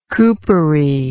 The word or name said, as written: coopery